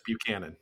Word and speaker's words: Buchanan: Yes, Buchanan.